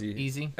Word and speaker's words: Eze: Eze. I think it's Eze. – Eze.